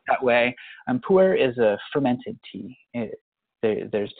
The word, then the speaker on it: Pu-erh: Puer tea, can you spell that puer? – Yeah, P-U-E-R-H, puer.